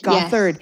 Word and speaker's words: Gothard: I feel like Bill, is it Gothard? Is that how you say it? – Gothard.